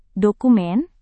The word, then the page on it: dokumen: Indonesian